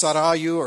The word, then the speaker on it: Sarayu: Now, I have been told by a community in Baton Rouge, Grace Community Church in Baton Rouge, that the proper pronunciation is sorry, you. But in the original language, it's Sarayu or Sarayu.